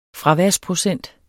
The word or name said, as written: fraværsprocent